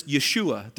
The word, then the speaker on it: Yeshua: Jesus is actually a Hebrew name, and in Hebrew, you pronounce this Yeshua. There's no J's actually in the Hebrew alphabet, and so you use a Y as Yeshua, and translated into English, it's actually Joshua from Hebrew.